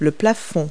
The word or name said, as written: ceiling